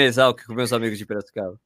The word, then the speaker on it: Piracicaba: É, peraí, peraí, você está falando errado o nome da cidade. – É Qual é Piracicaba.